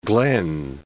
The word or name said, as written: Glen Canyon Dam